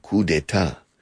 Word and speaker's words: coup d'état: In francés, coup d'état, pronunciado coup d'état.